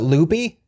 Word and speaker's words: Lupe: And then Scott's little sister, spelled like it would be Lupe, you know, like the, you know, Hispanic kind of name, but they pronounce it Loopy.